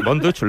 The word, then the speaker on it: Von Dutch: Así que, Von Dutch, ¿lo he pronunciado bien? – Sí, muy bien.